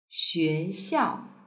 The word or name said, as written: xuexiao